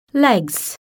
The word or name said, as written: legs